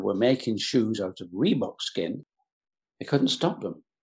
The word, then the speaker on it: Reebok: My dictionary is American dictionary, and had I been looking at an Oxford English dictionary, it would have been R-H-E-B-O-K. And sometimes R-H-E-B-O-C-K. Nothing like the Reebok, just R-W-E-B-O-K. Two syllables, brilliant.